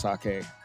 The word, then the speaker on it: sake: And finally, I'm going to close out with my new passion, sake. It's not sake, it's pronounced sake.